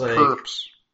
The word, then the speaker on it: PRPS: It's pronounced perps.